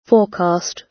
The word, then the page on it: forecast: British English